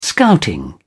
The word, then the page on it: scouting: British English